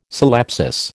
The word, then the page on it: syllepsis: American English